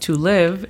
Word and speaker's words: live: La pronunciación fonética es simplemente L-E-V. Nada más toma en cuenta que la E aquí es una E corta, no es live.